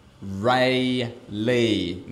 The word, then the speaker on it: Raleigh: I let it go a first few times, but for the love of God, it's pronounced Rayleigh, North Carolina.